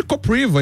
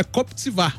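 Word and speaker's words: Kopřiva: Porque, por exemplo, tem um tenista que é o Koptsvar, mas se escreve Kopriva. Então todo mundo chamava ele de Kopriva e tal. Aí ele não, é Koptsvar, porque em tcheco, né, se fala assim.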